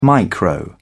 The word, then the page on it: micro: British English